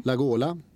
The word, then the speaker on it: Lagola: Som det ska uttalas har jag lärt mig av Skavlan, Lagola som Carola.